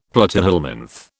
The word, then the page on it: platyhelminth: British English